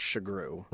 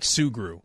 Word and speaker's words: Sugrue: Well, the way it's spelled, we always joke it's there's the invisible H because it is spelled Sugrue, but it's pronounced Shagru. – Right. Not Sugru.